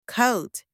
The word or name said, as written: coat